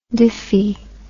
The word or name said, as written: diphy